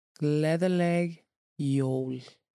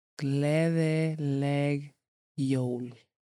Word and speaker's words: Gleðileg Jól: For saying Christmas or Merry Christmas to somebody, if you would like to, it is Gleðileg Jól. And I'll say it one more time. Gleðileg Jól.